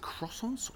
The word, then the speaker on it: croissant: Is it croissants or croissants? – It's croissant. – Croissant. Is that croissant with a C? – It's like CW? – Croissant? It's spelled CR, but it's pronounced qua.